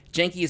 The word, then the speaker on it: Jaenki: Janky is spelled J-A-E-N-K-I. Janky is made up of three people who are no strangers to music.